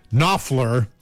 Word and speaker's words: Knopfler: Dire Straits, so far away, the K in Knopfler is silent, just in case you wanted to know.